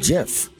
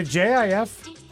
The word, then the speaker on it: GIF: GIF. – Why? It's not a JIF.